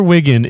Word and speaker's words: Wiggin: Ender Wiggin. Go back to Spago. No, fuck you. – The movie about young Ender Wiggin